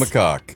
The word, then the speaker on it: macaque: Is that how you say it? – Yeah, macaque? Macaque. – Yes. – It is macaque.